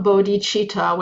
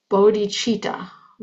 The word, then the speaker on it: bodhicitta: So it's not bodhicitta, wakeful mind, because that makes the meter not work. It's bodhicitta with the underline in the on the first syllable.